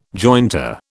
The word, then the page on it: jointer: British English